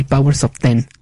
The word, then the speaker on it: Powers of X: Hoxbox, House of X y Powers of Ten, aunque se escribe Powers of X.